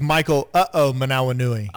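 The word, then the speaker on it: Michael Ho'omanawanui: It's Michael Uh-oh Manawanui. – Oh, okay. Uh-oh Aminui. – Oh, he's so funny. – Uh-oh Aminui. – I'm dead serious. Michael Uh-oh Manawanui. – I knew his nickname was Uh-oh. – Yeah. That's how you pronounce it. Uh-oh, Manawanui.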